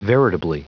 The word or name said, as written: veritably